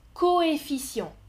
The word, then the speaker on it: coefficient: Regardez et écoutez la prononciation du premier mot, coefficient. Les deux lettres sont écrites séparément, ok C'est o e 2 f i c i e n t Coefficient. Je prononce bien deux sons différents.